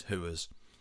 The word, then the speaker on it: whores: But it doesn't work unless you know the Scouse pronunciation of whores, which is Hooers.